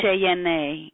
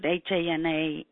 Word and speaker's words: Jana: I'll bet you people mess up your name all the time, because phonetically it looks like it would be Jana or Jana. – Correct. – And so you'll want to put in parentheses. Rhymes with Hana. Well, I always put Hana and in parentheses usually.